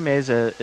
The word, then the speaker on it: Meza: I'm disappointed that it's S-U-I-T-E, Sweet, because when you said Lily Massa and Sweet Unraveling, I was just thinking, Sweet Massa. That sounds delicious. – Sweet, yeah, no. Lily Meza is... – Meza, is that how you say it? – She's amazing.